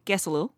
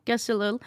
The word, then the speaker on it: Kesalul: It's spelled K E S A L U L. Can mm-hmm. you pronounce it for me? Gesalil. Gesalil. Uh, and then, so that means I love you.